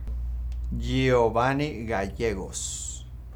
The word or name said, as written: Giovanny Gallegos